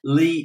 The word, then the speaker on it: Leigh: But if you just give it a search, Lee is spelled L-E-I-G-H.